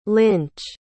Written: linch

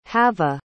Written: hava

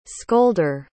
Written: scolder

